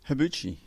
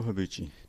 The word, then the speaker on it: Habbouche: Habbouche. Nabil Habbouche.